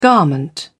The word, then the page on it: garment: British English